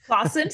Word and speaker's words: Claassen: So Andrea Claassen, did I say it right? – Claassen, so yes. Yeah.